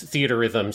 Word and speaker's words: Theatrhythm: If it's theaterism because it's shiatorism. So it's theaterism seems to be probably the best way to pronounce it. – Okay, because the R is just shared between the two words, and that's just confusing.